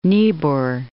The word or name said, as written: Niebuhr